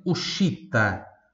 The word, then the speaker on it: uscita: Outra palavrinha aqui de exemplo, Ushita. Ushita. Se escreve U-S-C-I-T-A.